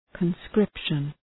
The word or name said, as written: conscription